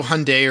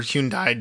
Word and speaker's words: Hyundai: And it's not just Kia, it's also Hyundai or Hyundai depending on your how you prefer to pronounce that car manufacturer.